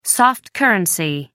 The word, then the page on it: soft-currency: American English